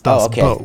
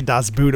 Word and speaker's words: Das Boot: I thought it was always Boot. – Our German friend told us it's Das Boot. We only Americans say Das Boot, apparently.